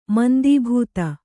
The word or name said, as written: ಮಂದೀಭೂತ